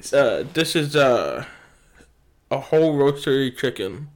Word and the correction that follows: rotisserie: Okay, cool. Rotisserie. – Rotisserie chicken. There you go. That's how you say it.